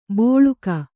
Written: ಬೋಳುಕ

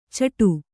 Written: ಚಿಟ್ಟು

